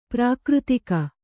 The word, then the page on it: ಪ್ರಾಕೃತಿಕ: prākřtika